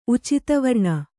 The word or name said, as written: ಉಚಿತವರ್ಣ